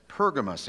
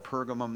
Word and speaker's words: Pergamum: So now the New King James Version, which we all know is the correct version, it says Pergamus in here. Actually, in that area of the world, they call it Pergamum. That's probably more the correct pronunciation and spelling of it. So you may hear me go back and forth with that because I've heard it both ways.